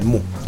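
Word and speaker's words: MØ: Bueno, ya saben a quién me refiero, Mu, se pronuncia Mu.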